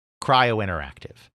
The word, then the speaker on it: Cryo Interactive: The outside company that they end up connecting with is a small French company by the name of, we'd call it Cryo in English, but I suppose it's pronounced like Cryo Interactive. In French, Cryo Interactive.